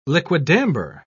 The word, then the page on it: liquidambar: British English